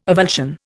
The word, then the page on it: avulsion: Indian